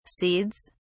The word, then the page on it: seeds: British English